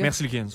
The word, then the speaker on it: Merzlikins: Merzlikins.